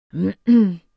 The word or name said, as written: hm